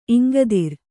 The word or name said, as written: ಇಂಗದಿರ್